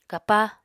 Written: kapa

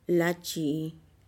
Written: latchi'i

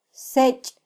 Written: sech

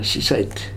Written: Sisait

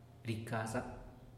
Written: ricaza

